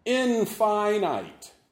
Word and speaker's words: infinite: Infinite is one when we are referring to God. Lengthen those I sounds.